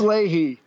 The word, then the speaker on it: Leahy: How do you pronounce it? – Leahy. – Leahy. Lay. – Is it Y on the end? Leahy. Yeah, Lay. Lay. Leahy. – Fucking Australian. Lehigh. It's Leahy.